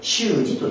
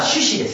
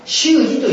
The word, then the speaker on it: 種子: 植物の種に例えて習字と言います。まあ、しかしこの植物の種と全くイコールではありませんから、あえて読み方を変えて、普通は種子ですけどね、有識学では習字という読み方をして、この植物の種に似たような働きなんだと。